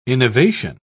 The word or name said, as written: innovation